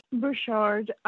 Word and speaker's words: Burchard: Burchard. Some of his family on the East Coast pronounce it Burchard, but here he pronounced it Burchard.